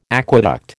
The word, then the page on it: aqueduct: American English